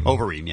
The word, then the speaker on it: Overeem: We also have Alistair Overman. Overeem. Am I saying that right? – I think it's Overeem, yeah.